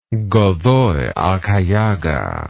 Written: godoy alcayaga

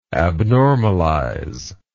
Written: abnormalization

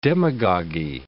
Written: demagogy

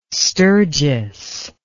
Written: sturgis

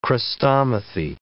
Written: chrestomathy

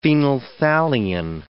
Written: phenolphthalein

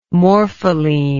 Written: morpholine

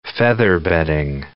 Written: featherbedding

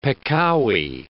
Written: peccavi

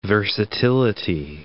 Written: versatility

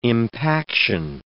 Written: impaction

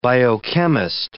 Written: biochemist

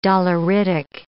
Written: doleritic

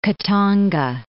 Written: Katanga

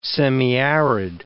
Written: semiarid

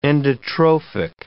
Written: endotrophic

